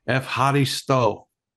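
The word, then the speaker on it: Evharisto (Ευχαριστώ): It's Evharisto. – Ah, thank you. – You're right. Did I say it right? – Yeah, Evharisto.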